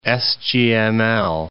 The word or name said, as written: SGML